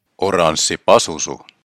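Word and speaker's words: Oranssi Pazuzu: The first thing I noticed is that Aranzi Pazuzu sounds better when it's pronounced in Finnish. – Oranssi pasusu.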